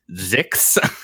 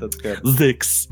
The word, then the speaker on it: Xix: Last time on Cosmic Crit, you all met Zix, which is spelled X-I-X. Oh. Very confusing. Oh, that's good. – Zix.